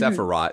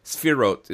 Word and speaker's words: Sefirot: Um, it looks like it's mm-hmm. Sephiroth, mm-hmm. – but it's Sefirot is the way I've heard it pronounced by people who speak Hebrew.